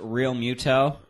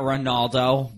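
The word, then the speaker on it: Realmuto: Ronaldo also, right? – Yeah, yeah, they did. And by the way, it's pronounced Real Muto. – Yeah, yeah, yeah. – It's not Ronaldo, buddy, okay? – Yeah.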